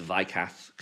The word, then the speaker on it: Weikath: and uh, Michael, I mean, if I suppose in German it'd be pronounced Weikath because that's the pronounce W's.